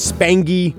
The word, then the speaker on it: Spangy: It is Spangy. I do not like when they mix the hard G with the soft G from the nickname to the actual name.